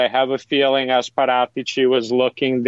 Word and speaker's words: Paratici: Is it the sporting director, who I'm about to maybe pronounce incorrectly as Fabio Paratici? Is it Andrea Pirlo? Is it someone else? Like, who do you think has made this decision to get Weston McKennie to Juventus? – I'm fairly certain it's sporting director Fabio Paratici. You came very close. Good pronunciation.